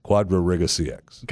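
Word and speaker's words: QuadrigaCX: It's Quad, Quad Riga, RIGA, capital C, capital X. Quadra Riga CX.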